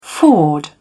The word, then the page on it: ford: British English